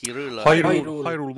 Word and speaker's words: Hyrule: Hyrule Warriors, Age of Calamity.